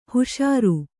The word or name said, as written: ಹಿಷಾರೆ